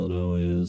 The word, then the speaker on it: Illinois: Illinois is, and I'm going to leave that s silent because I, I appreciate proper pronunciation.